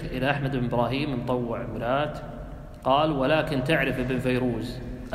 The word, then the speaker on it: مرات: مرات، هذه معروفة يعني بلدة في نجد تكتب مرات هكذا لكن تنطق مرات معروفة.